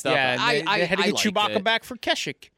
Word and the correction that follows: Kashyyyk: For a life day. – Kashyyyk. – Yeah, Kashyyyk. Thank you for my...